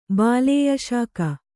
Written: ಬಾಲೇಯಶಾಕ

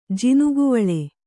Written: ಜೋನೆಗವಳಿ

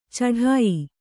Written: ಛಢಾಯ

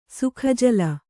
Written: ಸುಖಜಲ

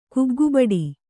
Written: ಕುಗ್ಗುಬಡಿ

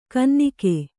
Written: ಗುನ್ನಿಗ

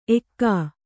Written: ಏಕ

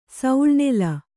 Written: ಸೌಳ್ನೆಲ

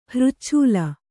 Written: ಹೃಚ್ಛೂಲ

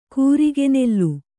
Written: ಕೂರಿಗೆನೆಲ್ಲು

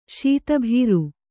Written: ಸುತ್ತುಬರು

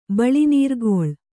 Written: ಬಳಿನೀರ್ಗೊಳ್

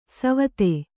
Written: ಸವಿಧೆ

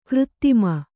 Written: ಗರುತ್ಮ

